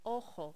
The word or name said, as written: ojo